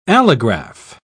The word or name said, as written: allograph